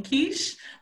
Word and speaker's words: Keysh: Yo, it's your girl, Queen Keish. My Instagram is queen.keish, and the number 3, Keish is spelled K-E-Y-S-H.